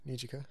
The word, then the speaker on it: Nijika: N-I-J-I-K-A. Nijika. – Nijika?